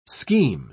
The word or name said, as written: scheme